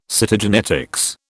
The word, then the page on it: Cytogenetics: British English